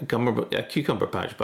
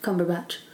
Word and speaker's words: Cumberbatch: A Cucumberpatch bug. – Yeah, but it's just Cumberbatch.